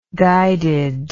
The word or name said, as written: guided